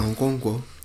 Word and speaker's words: Okonkwo: Okonkwo. – Oh, that's on point, Okonkwo.